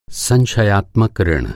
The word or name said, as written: संशयात्मक